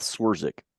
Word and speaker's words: Swerczek: It's Seth Swerzik.